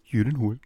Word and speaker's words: Gyllenhaal: gespielt von Jake Gyllenhaal, äh, der... – Es ist ein ähm, Jü Gyllenhaal. Gyllenhaal? Ja. Sag's mir nochmal. Gyllenhaal. Gyllenhaal. – Gyllenhaal.